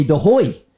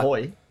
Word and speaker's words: De Goey: your name isn't pronounced Jordy De Goey. It's Jordy De Hoy. Should we be calling you Jordy De Hoy? – Nah, I think you shouldn't be calling me that at all, actually. I'll leave that up to the Dutchies. But, yeah, it's quite funny. If you go over to Holland, that's actually how they pronounce it, which is quite unusual.